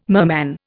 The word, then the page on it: merman: Indian